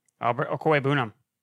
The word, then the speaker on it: Okwuegbunam: Go ahead. Say his name correctly. – Albert Okwuegbunam. – Thank you.